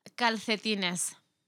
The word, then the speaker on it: calcetines: Calcetines.